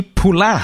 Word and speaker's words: Poullain: let's welcome onto the show Mr. Frankie Poullain. How are you, sir? Thank you for joining us. – Very good, and even better hearing my name pronounced correctly for the first time in 20 years.